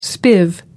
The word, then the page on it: spiv: American English